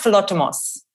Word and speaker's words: philotimo: And you probably can pronounce this better than me, but you write about this wonderful, is it philotimo, the Greek concept of big kindness. Can you explain that to our listeners? Because I think it's such a wonderful way to think about leading our lives. – It's awesome. It's pronounced philotimos, and it's a radical respect for kindness is roughly how it translates.